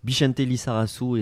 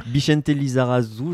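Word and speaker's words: Bixente Lizarazu: Donc, euh, Bichente Lissarasu et la bonne prononciation. Il faut que ce soit chantant comme, euh, comme un chant basque. Comme un chant choral.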